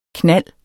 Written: knald

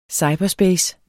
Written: cyberspace

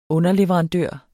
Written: underleverandør

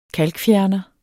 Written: kalkfjerner

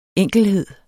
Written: enkelhed

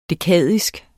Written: dekadisk

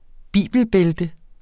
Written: bibelbælte

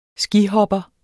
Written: skihopper

